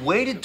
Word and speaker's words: waited: Wait，在它后面呢加上了 ed，要读作 waited，waited。 Move，末尾有一个摩擦音，要上齿摩擦下唇。 v，move。 Zone，zone 当中呢有一个双元音 o，要发音饱满。 – Why not? – Because you waited too long to make your move and now you're in the friend zone.